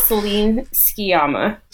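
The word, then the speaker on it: Céline Sciamma: Ceciline Sciamama? No, that's not right at all. – Well, director, I know her. That's Celine Sciamma. – Celine Sciamma.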